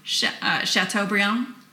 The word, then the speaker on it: Chateaubriand: Do you know Chateaubriand? – Yes, you said it right.